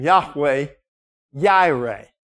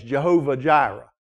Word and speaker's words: Yahweh Yireh: Hence, we get God's name, Yahweh, Yireh. Or those who had used the J's, Jehovah Jireh. But probably better pronounced, Yahweh Yireh.